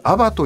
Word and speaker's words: ABA: 先週来ていただきまして前回ですね、はい、もうめちゃめちゃお話面白かったんですけど、改めてそのウイさんが今やられているアバという会社、 ABA と書いてアバなわけですけど、どういう会社なのかっていうのを教えていただけますか。